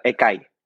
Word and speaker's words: Ekai: Yeah, but I want to Aniol, and then it's Ekai. – Yeah, Ekai. – Ekai. – Yeah, exactly.